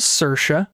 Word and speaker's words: Saoirse: Her name is Saoirse. That's how you pronounce it.